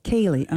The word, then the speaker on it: Ceilidh: A Ceilidh is kind of a Celtic music party. – I was wondering how to pronounce that. C-E-I-L-I. – I had to look it up. – Ceilidh, okay.